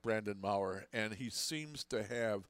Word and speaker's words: Maurer: Is his last name pronounced differently than it's spelled? – Yes. – Yeah, Maurer.